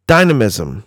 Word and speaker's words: dynamism: Things that define him are pace and dynamicism. I think I said that correctly. – Nope, but that's okay. – Dynamism. – There you go.